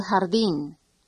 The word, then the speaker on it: jardín: El jardín. El jardín. – Remember, the J is pronounced like a strong English H.